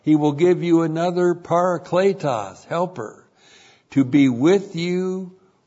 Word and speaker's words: parakletos: P-A-R-A-K-L, long E-T-O-S, parakletos.